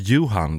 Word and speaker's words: Johan: You don't pronounce it like that. It's Johan, like you won a shit of load of money at the lottery.